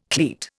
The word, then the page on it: cleat: Indian